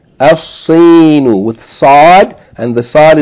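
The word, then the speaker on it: Al-Sinu (الصين): Yani, instead of saying Al-Sinu, like we said, El-Yabanu, here the first letter of the word, Sin, is a sun letter. So we're going to, yani, merge the lam into the sun letter. We're going to double the sun letter and pronounce it Al-Sinu.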